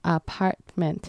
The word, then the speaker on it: apartment: 那还有在 apartment 那边，它是有三个 syllable，它在 part 的那个 t 可以一样也是很轻微的去发音。还有最后那个 M-E-N-T，它的发音就跟那个薄荷糖一样，mint，广东话就是薄荷糖。